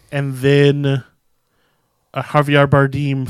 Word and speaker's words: Bardem: So we get Jennifer Lawrence, who's mother, and Javier Bardem. Am I saying that right? – Bardem. – Bardem. – Yeah, it's an A.